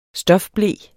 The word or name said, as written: stofble